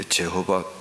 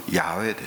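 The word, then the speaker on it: ヤハウェ: イスラエルの王はイエス・キリストです。ヤーウェ、ヤーウェ、まあ、イエホバだと言ってるけどね、イエホバは人間がこのイエハプハという発音を、アドナイというそこに音化を入れてジェホバと呼んだんですけど、本当はヤウェですよ、ヤウェ。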